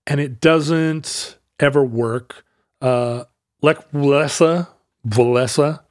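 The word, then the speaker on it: Wałęsa: And I guess I should be pronouncing it Walesa. Walesa. – For some reason, there's a N sound.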